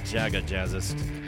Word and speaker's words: Jaga Jazzist: Otherwise, behind me from Norway, this is Jaga Jazzist, or I guess you pronounce the J's North American way, Jaga Jazzist.